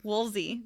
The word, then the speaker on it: Woolsey: Woolsey.